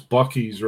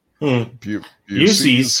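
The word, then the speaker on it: Buc-ee's: was the anger about continuing to pronounce Bucky's wrong. – Busey's, sir. Yeah.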